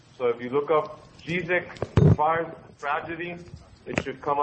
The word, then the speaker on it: Žižek: Under the name of the philosopher, which is, you pronounce it in Slovenian, so it's pronounced as Žižek. Žižek is written with Z, Z-I-Z-E-K.